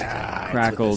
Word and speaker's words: Crackle: It is just Crackle, just the way it's spelled. – Fine. C-R-A-C-K-L-E.